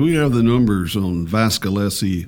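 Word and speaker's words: Vasilevskiy: What's his name? It's Vasilevsky. – Vasilevsky. – Vasilevsky. – Vasilevsky. It's like Vaseline. Vasilevsky.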